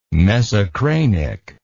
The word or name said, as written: mesocranic